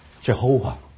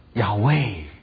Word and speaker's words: Yahweh: 但是我们要知道这不是神原来真正的名字，是因为把 Adonai 的母音灌到亚卫这个字的子音的下面，产生一个新的读音 Jehovah。但是神的名字原来是 I am，我们称神是 Yahweh。